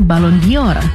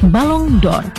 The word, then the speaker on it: Ballon d'Or: Cara pengucapannya pun sering keliru. Banyak yang menyebutnya Ballon d'Or. Padahal dalam pelafalan bahasa Prancis yang lebih mendekati adalah Ballon d'Or.